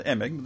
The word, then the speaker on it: Emig: Uh, Emig, the softie.